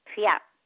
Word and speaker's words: Piotr: it's actually P-I-O-T-R. The R is silent.